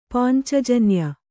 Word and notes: ಪಾಂಚಜನ್ಯ: pāncajanya

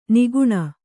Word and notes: ನಗಣ: nagaṇa

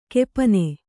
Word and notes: ಗೋಪನ: gōpana

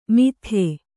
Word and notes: ಮಿಥ್ಯೆ: mithye

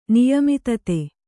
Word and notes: ನಿಯಮಿತತೆ: kannada

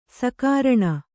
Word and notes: ಸಕಾರಣ: sakāraṇa

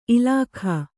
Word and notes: ಇಲಿಕೆ: ilike

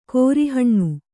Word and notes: ಕೋರಿಹಣ್ಣು: kōri haṇṇu

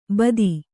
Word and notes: ಭಾತಿ: bhāti

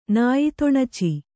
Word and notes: ನಾಯಿತೊಣಚಿ: Kannada

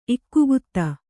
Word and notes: ಇಕ್ಕುಗುತ್ತ: ikkugutta

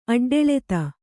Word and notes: ಅಡ್ಡೆಳೆತ: aḍḍḷeta; Kannada

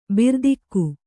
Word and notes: ಭೃತಕ: bhřtaka

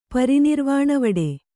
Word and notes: ಪರಿನಿರ್ವಾಣವಡೆ: Kannada

